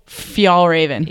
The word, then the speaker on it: Fjällräven: Fjällräven.